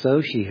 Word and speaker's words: Xochitl: And he said, "It's Soshi."